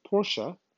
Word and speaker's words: Porsche: So Porsche, yes, it is pronounced Porsche.